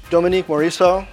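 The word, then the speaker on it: Dominique Morisseau: Dominique Morisot. I said it right.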